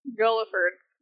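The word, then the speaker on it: Guildford: So, John Dudley was attempting to have his son, and his son's name was, I don't really know if I'm going to pronounce this right, but, Gulliford? – Gulliford. – Yeah. Gulliford. – G-U-L-I-F-O-R-D.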